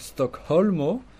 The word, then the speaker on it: Stockholmo: Donc, Stockholmo, il faut bien prononcer le H en espéranto, il faut bien prononcer toutes les lettres.